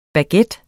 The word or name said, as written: baguette